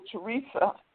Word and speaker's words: Theresa: But my name is spelled T-H-E-R-E-S-A, last name Elamine, E-L-A-M-I-N. And if people Google Teresa Elamine Papers, they will see what's deposited at the Sally Bingham Center for Women's History and Culture at Duke University Library.